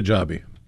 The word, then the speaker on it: Hijabi: Red State, Mississippi, just appointed America's first Yemeni Sharia Hajibi, I don't know if I pronounced that correctly or not, judge. The user claimed Sharia Hijabi. – Hijabi, okay. I accented the wrong syllable there.